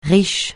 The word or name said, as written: riche